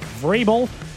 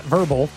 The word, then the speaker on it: Vrabel: Mike Vrabel, not verbal, the coach of the year.